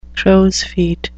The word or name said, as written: crows feet